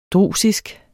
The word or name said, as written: drusisk